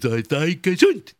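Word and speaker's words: zay gezunt: Yes, Zai Gesund. Did I say it right? – Yeah, yes, you did.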